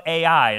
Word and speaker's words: Ai: And so the next city that's to be taken is the city of Ai, is how you say it. It's Ai, is literally how it's spelled, and you pronounce it Ai, interesting.